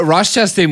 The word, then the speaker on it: Ross Chastain: No, Ross Chastain was super impressive yesterday.